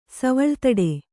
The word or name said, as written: ಸವಳ್ತಡ